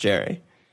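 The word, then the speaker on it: Yerry: It's a Y, but it apparently is pronounced Jerry.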